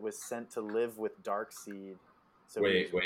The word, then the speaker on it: Darkseid: It's pronounced Darkseid. – Darkseid. – I used to do the same thing. I'd always say Darkseid. But then I saw one of the cartoons where they call him Darkseid.